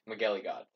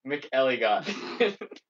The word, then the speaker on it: McElligott: Dominic McElligott. Fuck, I said it wrong. McElligott. – Okay, good to know.